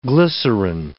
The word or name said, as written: glycerin